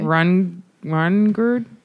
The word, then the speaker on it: Hrungnir: Then there's a drunken Norse giant, and I don't know if I can say his name. – Okay. – Rungird, H-R-U-N-G-N-I-R.